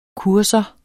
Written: kursus

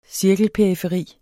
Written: cirkelperiferi

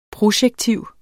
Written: projektiv

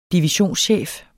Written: divisionschef

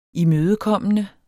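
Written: imødekommende

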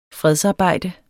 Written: fredsarbejde

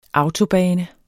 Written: autobane

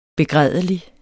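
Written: BEGRÆDELIG

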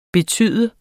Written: betyde

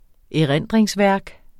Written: erindringsværk

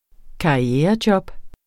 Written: karrierejob

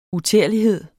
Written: uterlighed

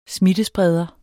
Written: smittespreder

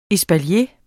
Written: espalier

